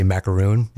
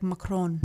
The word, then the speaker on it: macaron: We both say it wrong, but we say macaroon, which isn't right, right? – Yeah, it's so macaron. Fancy. It's like macaron.